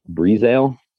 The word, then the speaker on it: Breazeale: But his name is Dwayne Brizale. I believe Brizale is how he pronounces his name.